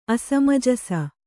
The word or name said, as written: ಅಸಮಜಸೆ